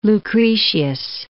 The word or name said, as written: Lucretius